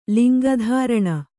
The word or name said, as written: ಲಿಂಗಧಾರಣೆ